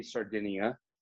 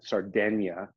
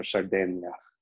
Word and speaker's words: Sardegna: Yeah, it's the Americans say Sardinia. But in the Italians, they got that GN, Sardegna. – Okay. – That's how they say it. So Sardegna.